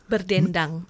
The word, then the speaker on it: Berdendang: My terrible Western pronunciation, Berdandang. – Berdendang. – Berdendang, yes.